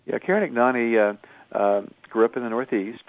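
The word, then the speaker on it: Ignagni: and I don't know if I have the pronunciation of her last name right, Karen Ignani? – Ignani.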